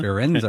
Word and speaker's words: Firenze: But if, like, Florence, Italy is pronounced Florence in America, it's pronounced... – Firenze or something Firenze. Close to that.